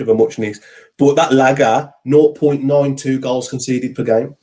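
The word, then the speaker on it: Lage: And, okay, yes, a hell of a lot of games managed less, but it's pronounced Lager.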